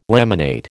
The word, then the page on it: laminate: American English